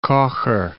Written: Kocher

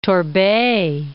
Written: Torbay

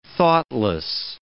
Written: thoughtless

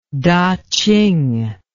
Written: taching

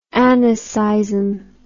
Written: anaseism

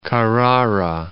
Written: Carrara

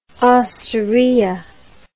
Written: osteria

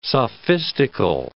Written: sophistical

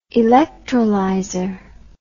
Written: electrolyzer

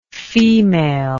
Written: femaleness

